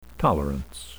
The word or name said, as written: tolerance